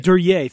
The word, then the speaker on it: Duryea: Another big name who starts in bicycles and goes to automobile manufacturing is Charles Duryea. I'm not sure if I'm pronouncing that correctly. Please correct me. But Duryea. – I love Duryea. Thank you.